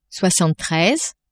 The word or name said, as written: soixante treize